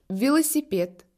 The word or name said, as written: velo